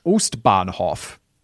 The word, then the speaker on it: Ostbahnhof: With German pronunciation, I tend to just say it in a German accent, like Ostbahnhof. That sounds more Dutch. Ostbahnhof. Maybe. This was advice, and I was like, doesn't it just sound a bit racist? And people were like, no, but it does actually sound closer to the real pronunciation.